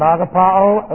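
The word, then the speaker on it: agapao (ἀγαπάω): And agapao, A-G-A-P-A, long O, agapao, meaning to love.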